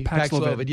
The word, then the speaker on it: Paxlovid: And now with these rebounds off Paxil, did I say that right? I always seem to say it wrong. I just pick a different drug that starts with a P. – Paxlovid, yeah.